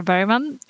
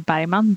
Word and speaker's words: Bergman: Hi, my name is Sara Bergman, or Sara Bergman, Bergman. I never know how to say it in English because it's so different from the Swedish.